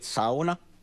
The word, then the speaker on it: sauna: We pronounce it sauna. That's the correct the correct pronunciation.